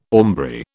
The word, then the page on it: aumbry: British English